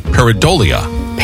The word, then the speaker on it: pareidolia: P-A-R-A-D-O-L-I-A. Pareidolia. – So close. – Just a bit outside. – Really? – Yeah. P-A-R-E-I. Silent I in there. D-O-L-I-A. So pareidolia with a silent I in the middle of it.